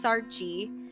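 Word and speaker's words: Saartjie: Her real name is Sarchi, and I'm so sorry for butchering it. It's Sarchi. Sarchi Barman.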